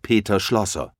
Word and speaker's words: Peter Schlosser: Mein Name ist Peter Schlosser. – Können Sie das buchstabieren? – Gerne. P-E-T-E-R und dann S-C-H-L-O-S-S-E-R.